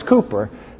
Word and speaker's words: Cowper: Looks like it's Cowper, but it's Cooper, how he pronounced it.